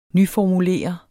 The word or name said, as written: nyformulere